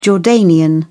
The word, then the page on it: jordanian: British English